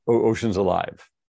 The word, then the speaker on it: Oceans Alive: I'm definitely gonna have to go check out Ocean's Life. Am I saying it right? Ocean's Life or Ocean's Life? – Ocean's Alive. – Ocean's Alive. Ocean's Alive. Yeah. Okay, Alive.